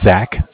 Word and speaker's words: Zach: It's Zach, spelled Z-A-C-H.